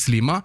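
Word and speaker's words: Sliema: Slima, staveste, okay, ja. Men det uttales Slima.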